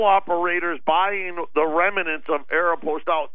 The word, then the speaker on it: Aeropostale: Is that how you pronounce it, Aeropostale? – Yeah, Aeropostale. – I did not know that. I've been saying it wrong the whole time.